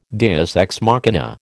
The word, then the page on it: deus ex machina: American English